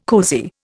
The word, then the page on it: cosy: Indian